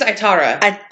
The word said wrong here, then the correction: Aitana: Aitara or Aitana? It's got an N. – I'm sorry. It is Aitana.